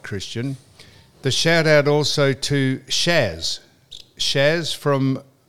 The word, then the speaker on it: Syaz: And her name is spelt S-Y-A-Z, but it's... – S-Y-A-Z. – It's pronounced Shaz. It's pronounced Shaz.